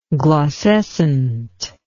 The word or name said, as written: glaucescent